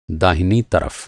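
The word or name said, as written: दाहिना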